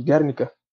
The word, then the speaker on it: Guernica: à cidade espanhola de Guernica. Acho que se pronuncia assim, Fernando. Assim, e se não se pronunciar assim, você me corrija, por favor.